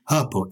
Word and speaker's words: hirple: I say, because something does rhyme with purple. I didn't think anything did, but Susie told me the word is herple, H-I-R-P-L-E.